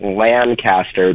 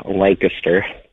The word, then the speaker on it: Lancaster: Also, and I know you Californians say it Lancaster, but it's actually pronounced Lancaster.